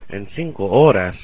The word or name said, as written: en 5 horas